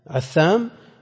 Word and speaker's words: عثام: وإذا نص على أشخاص بأعيانهم دل على أن من عداهم بخلافهم، والعامري بن علي عثام بالعين والمثلثة عثام وغيره.